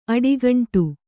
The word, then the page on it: ಅಡಿಗಂಟು: kannada